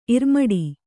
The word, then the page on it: ಇರ್ಮಡಿ: kannada